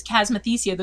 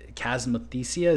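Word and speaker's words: chasmothecia: So, does tilling the soil have any positive effects in terms of potentially killing the chasmothesia? Is that the correct word? Am I saying it right? – Yeah, that's right.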